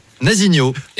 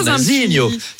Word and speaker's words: Nazinho: Nazinho, eh oui, Nazinho. – C'est vrai que ça se prononce avec l'accent chantant, Nazinho.